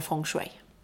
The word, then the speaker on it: feng shui: Det är så här att feng shui, vet du, feng shui, det heter egentligen feng shui. – Feng shui. – Det gör det faktiskt. Man uttalar det så.